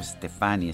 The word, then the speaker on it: Stefani: Eh, seguramente usted se va a preguntar, bueno, ¿por qué lo pronuncio Stefani, este apellido? Bueno, porque esa es la forma en que se pronuncia.